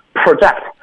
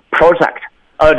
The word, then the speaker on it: project: project 它的动词是 project，这个名词是 project，动词是 project，它的发音要发生变化，它的意思就是预测。